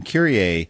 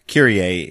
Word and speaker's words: Kyrie: Currier, and you mentioned this to me when we were talking earlier on the phone, Ash, that Currier, I knew how to pronounce it because I was raised Catholic, and Currier eleison, I mean, I actually know it. So Currier is K-Y-R-I-E.